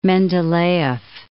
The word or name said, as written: Mendeleyev